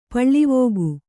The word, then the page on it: ಪಾಲ್ವಕ್ಕಿ: pālvakki